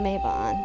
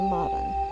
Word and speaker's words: Mabon: and Mabon or Mabon, depending on your pronunciation.